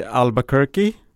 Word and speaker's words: Albuquerque: Ja, det, det är inte ett garage i Albuquerque.